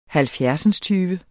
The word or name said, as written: halvfjerdsindstyve